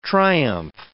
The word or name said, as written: triumph